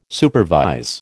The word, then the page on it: supervise: American English